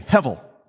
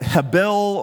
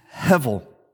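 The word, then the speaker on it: hevel (הֶבֶל): Say that word with me, hevel. Some would pronounce it hebel or hevel. We're going to use hevel.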